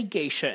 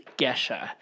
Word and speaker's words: Gesha: G-E-S-H-A. – Geisha. We do not say Geisha in this house for many reasons. That's appropriation. No Geishas. It's Geisha.